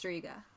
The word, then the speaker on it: Striga: It's Albanian. So if we're pronouncing it incorrectly, we are very sorry. But I believe it's pronounced Striga.